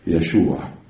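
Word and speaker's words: يشوع (ישוע): يسوع تنطق بالعبرية يشوع.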